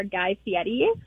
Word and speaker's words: Guy Fieri: I would absolutely fangirl over Guy Fieri.